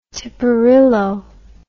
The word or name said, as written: tiparillo